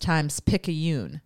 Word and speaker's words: Times-Picayune: So he also told me that this is pronounced Times-Picayune. So the Times-Picayune...